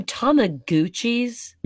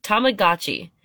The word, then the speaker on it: Tamagotchi: You know, by the way, going into this last break, you called them Tamaguchis? – Yeah, whatever the names are. I don't even know how to spell it. Tamagotchi? – Yes, Tamagotchi. Please don't call them Tamaguchis.